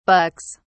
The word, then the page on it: bucks: British English